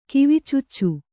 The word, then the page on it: ಕಿವಿಚುಚ್ಚು: kivicuccu